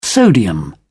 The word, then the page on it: sodium: British English